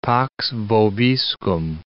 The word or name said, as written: pax vobiscum